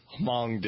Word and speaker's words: Hmong: making fun of Hmong Americans, H-M-O-N-G.